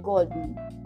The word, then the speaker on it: golden: Es como si suprimieras la E de la palabra den, golden. Se escribe así, pero se pronuncia golden.